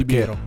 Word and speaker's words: Biro: Cioè, in realtà Biro, scusa, non okay, Biro. Perché infatti l'accento è chiuso. – Ah, ok. – Quindi Biro.